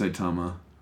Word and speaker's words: Saitama: Uh, uh no, it's Saitama.